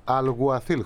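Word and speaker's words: Alguacil: Δηλαδή και ο καινούργιος προπονητής φαίνεται ότι πρέπει να προφέρεται Αλγουαθίλ, χωρίς το ΚΑΠΑ, αλλά τέλος πάντων.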